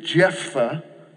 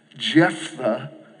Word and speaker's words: Jephthah: His name is Jephthah. Jephthah. That one's kind of, you gotta kind of practice that one.